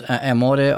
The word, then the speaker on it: Amore: And I believe it's pronounced Amore Artisan Boutique. Is that correct, Gail? – That is correct, yes.